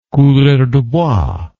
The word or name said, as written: coureur de bois